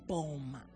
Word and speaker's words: Boom: The double O is a long O. Boat, as in Corey Ten Boom. That's how you say her name.